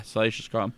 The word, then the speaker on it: Salacious Crumb: Comes with Salacious Crumb. Did I say that right? – Yeah. – Yeah, Salacious Crumb.